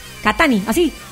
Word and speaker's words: Catani: Catani, así. Como katana. – Sin acento en la I. No, no, Catani. Catani.